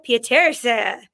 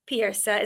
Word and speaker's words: Pieterse: I'm your host, Holland Roden, and I am so excited to announce this week's guest, Sasha Pieterse. Am I pronouncing this correctly? That was a no. The shake was a no. – No, it's so close. It's Pieterse.